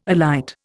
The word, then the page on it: alight: Indian